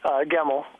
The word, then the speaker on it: Gemmell: Gemmell, but it gets pronounced many ways, so okay.